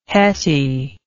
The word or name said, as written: hetty